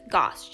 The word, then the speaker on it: Goss: But my mother, when she married my father, decided to keep her maiden name, but I got my father's name, which is Goss, G-O-S-S.